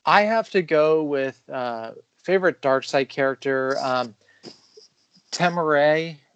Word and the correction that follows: Temuera: Morrison. God, I hope I didn't butcher his first name. – Temora. – Thank you.